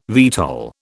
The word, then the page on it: VTOL: British English